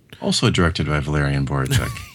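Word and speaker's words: Borowczyk: directed by Valerian Borochek. I believe that's how you say his name anyway, Borochek. – Sounds good to me.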